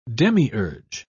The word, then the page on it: demiurge: British English